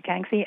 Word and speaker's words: Kangxi: It was the Kangxi Emperor, which is not easy to pronounce when you see the spelling, but that's what it was, the Kangxi Emperor.